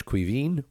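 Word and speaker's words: Caoimhín: And so, for example, my name is Kevin, which comes from the Irish Cuivin, which is spelled C-A-O-I-M-H. I for the N, the M-H serving for the V.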